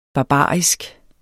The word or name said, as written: barbarisk